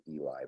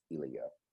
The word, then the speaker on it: Ilya: I was thinking it was Eli, but it's Ilya.